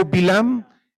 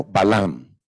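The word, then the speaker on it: Balaam: En español, Balam, gracias, Rabino. En, hebreo, Bilam, en castellano, Balam.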